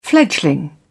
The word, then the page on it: fledgling: British English